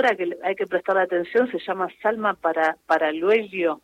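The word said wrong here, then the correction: Paralluelo: sería bien pronunciado para Uyuelo, sí.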